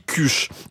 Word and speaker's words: kush: Alors, ça s'écrit K-U-S-H, moi je dis cuche.